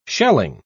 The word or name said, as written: shelling